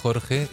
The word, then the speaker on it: Jorge: Tu l'as très très bien prononcé, ce que j'allais dire. C'est quand même très bien, tu as bien dit Jorge, chose qui n'est pas facile. – Ouais.